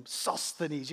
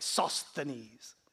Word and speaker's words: Sosthenes: Sosthenes. You got to have a lisp to say it right. Sosthenes.